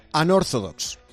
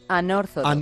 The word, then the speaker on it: Unorthodox: ¿Cómo se dice entonces? – Unorthodox. – Unorthodox. – Unorthodox, ya está.